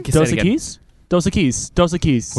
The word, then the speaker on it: Dos Equis: Dos Equis.